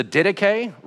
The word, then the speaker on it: Didache: So I'm not going to pronounce this right, but in case you're trying to Google it, it's the Didache, right? It's one of the earliest Christian documents we have. D-I-D-A-C-H-E. That's not how it's pronounced, but that will sound a little better.